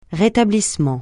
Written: rétablissement

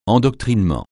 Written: endoctrinement

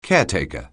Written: caretaker

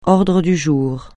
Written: ordre du jour

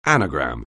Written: anagram

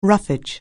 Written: roughage

